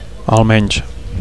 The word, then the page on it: almenys: Catalan